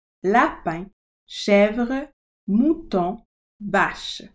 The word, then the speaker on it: lapin: souris, mouse, lapin, rabbit, chèvre, goat, mouton, sheep, vache, cow, chien, chat, souris, lapin, chèvre, mouton, vache.